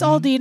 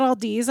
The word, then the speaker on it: Aldi: Good job, Aldi's. – They bring it right to you from Germany. – Aldi, you're doing a great job. It's Aldi, not Aldi's.